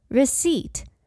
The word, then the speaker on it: receipt: Receipt. R E C E I P T，虽然这个字里面有一个 P，但是 P 是不发音的。